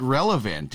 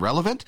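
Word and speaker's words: Relevnt: So all you have to do is download the app from Relevant. And remember, the last E is silent. So it's R-E-L-E-V-N-T.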